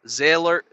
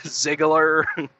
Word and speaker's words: Ziegler: I've heard Ziegler. I think somebody even said like Ziller or something. Like, I guess they thought the G was silent. Ziegler.